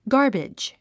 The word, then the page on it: garbage: ɡάː r bidʒ; ガーベジ